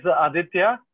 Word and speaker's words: Aditya: So you say it as Aditya, which means the king, the lord of the sun. It means the giver of life. And if you cannot pronounce it well enough, then it means you can say Adi, which is a short form, which most of my friends and family call.